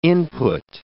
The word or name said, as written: input